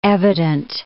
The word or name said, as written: evident